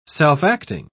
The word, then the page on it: self-acting: British English